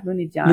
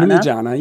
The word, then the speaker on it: Lunigiana: Lunigiana. Yeah. Lunigiana. – Lunigiana.